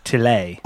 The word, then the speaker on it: Tyler: Tyler Labine. – It's pronounced Tillet. – Oh, Tillet Labine. That guy. – The R is French.